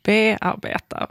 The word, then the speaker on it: bearbeta: Och varför tar du då helt plötsligt betoning i bearbeta? Varför säger vi inte bearbeta? Som vore det rimligt. Alltså din tyska föreläsare, han sa ju egentligen det som var rimligt. Och det är vi alla vi andra som har liksom gjort ett uttalsundantag just för det här ordet.